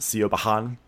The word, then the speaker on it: Siobhan: Siobhan is, you know, it's pronounced Siobhan. I mean, not pronounced, spelled Siobhan, but, you know, Siobhan.